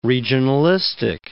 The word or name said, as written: regionalistic